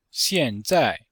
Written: xian4zai4

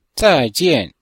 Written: zai4jian4

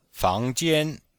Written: fang2jian1

